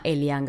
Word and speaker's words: Eliane: Eh, la prima de la ketchup se llama Elian Gallero. – La mayoría de la gente no sabe pronunciar bien mi nombre, entonces me pueden decir Eli.